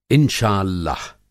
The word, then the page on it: इंशाअल्लाह: Hindi